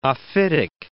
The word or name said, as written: ophitic